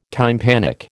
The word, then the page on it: tympanic: American English